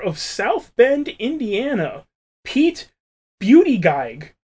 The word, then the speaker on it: Buttigieg: Well, he doesn't want to feature his last name, which I'm pretty sure is pronounced Buttigieg.